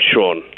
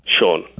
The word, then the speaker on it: Shawn: My name's Sean. – Sean. What's your real name, yeah. – Sean? – Sean. – Okay. How do you spell that? – S-H-A-W-N.